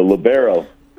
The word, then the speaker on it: libero: But the defensive specialist, or sometimes the point guard or quarterback, if you will, of volleyball, how do you pronounce that title? – The libero.